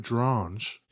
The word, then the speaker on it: Drange: So he left and went on to partner with Alfred Drange. – Rhymes with orange.